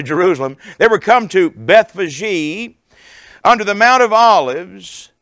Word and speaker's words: Bethphage: I've always pronounced it Bethphage. That's what it looks like to me. But the proper pronunciation is Bethphage. Three syllables. Bethphage.